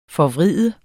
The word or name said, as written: forvride